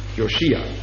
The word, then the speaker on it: Josia: Josia, sieh. Josia. Also auf dem I, Josia.